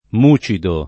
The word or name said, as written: mucido